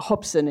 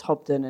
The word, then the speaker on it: Hobden: And then my husband's name is Hobden, which is rubbish, and no one can ever spell it. And they go Hobson, and you have to go, no, it's Hobden, and it's weird.